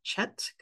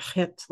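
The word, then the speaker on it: chet (ח): Western, maybe English pe speaking people would pronounce it chet because it starts with a ch, but I believe it's like chet, like a very kind of guttural pronunciation.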